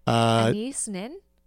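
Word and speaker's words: Anaïs Nin: Anais Nin.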